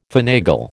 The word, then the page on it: finagle: American English